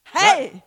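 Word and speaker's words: Hey (הא): Das Jahr von Hey. Hey. – That's how it's pronounced.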